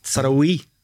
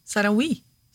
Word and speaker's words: Saraoui: Saraoui. – Ja, men det liksom, Oui skal være som en V-I. Saraoui.